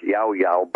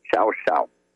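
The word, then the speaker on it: Llao Llao: In Spanish, that's pronounced Yao Yao, but in Argentine Spanish, it's Xiao Xiao.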